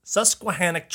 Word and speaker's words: Susquehannock: So, in any case, so we got this valley, and it's got the Saskatchewan tribe. Am I saying that right? – Susquehannock tribe, which was a tribe that spoke the language of the Iroquois, but they were just doing their own thing.